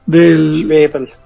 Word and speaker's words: Zweibel: Zweibel, como cebolla. – Ah, como cebolla, ok. – Como cebolla en alemán.